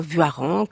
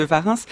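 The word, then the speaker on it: Warens: qui explique bien qu'il faut dire Vuaren comme un Aran ou comme un Varan. Alors c'est peut-être moins joli, mais c'est comme ça qu'il l'a dit lui-même.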